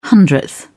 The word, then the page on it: hundredth: British English